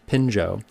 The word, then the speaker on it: Phinjo: My good friend Pinjo Sherpa, spelled P-H-I-N-J-O, but pronounced Pinjo,